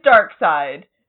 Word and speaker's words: Darkseid: Like, actually, me, for, like, several years of my life, I was like, Darkseid? Is it Darkseid? No, it is Darkseid. It is the very obvious pun. – I mean, Darkseid also is a very good name for a villain.